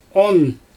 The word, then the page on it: on: Xyzyl